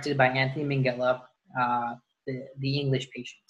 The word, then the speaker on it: Minghella: I mean, I know he's passed away, but his son, Max Minghella, pronounces his last name as Minghella. So... I'm going to call him Minghella.